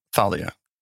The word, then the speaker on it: Thalia: How would you say it? How do you say it? – It's Thalia. – Thalia? – I would say, yeah.